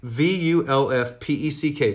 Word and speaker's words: Vulfpeck: But it's called Wolf Peck. V U L F P E C K. It's one word.